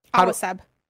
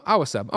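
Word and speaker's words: Awaceb: Awaseb. Awa, like water in Spanish, Awaseb. – Oh, Awaseb.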